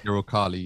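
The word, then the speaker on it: Uralkali: Ukrali, I think that's how you say it. – Ukrali, yeah.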